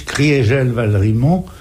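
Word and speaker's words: Kriegel-Valrimont: On prononce Criégel-Valrimont, c'est bien ça, ou Criégel-Valrimont? – On prononce Maurice Criégel-Valrimont pour marquer que en 18, l'Alsace a cessé d'être germanisée.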